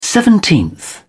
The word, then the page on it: seventeenth: British English